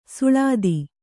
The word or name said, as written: ಸುಳಾದಿ